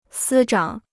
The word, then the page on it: 司长: sī zhǎng